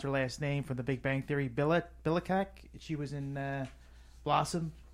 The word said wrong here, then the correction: Bialik: Oh, Maya Bialik. Maya Bialik.